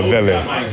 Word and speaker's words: Vele: Is it Vele or Vele? – It's a Vele. – Vele. – Yeah, Vele. – So not V-A-Y.